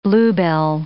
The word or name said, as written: bluebell